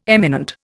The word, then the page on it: eminent: Indian